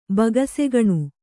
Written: ಬೊಗಸೆಗಣ್

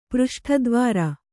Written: ಪೃಷ್ಠದ್ವಾರ

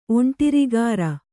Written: ಒಂಟಿರಿಗಾರ

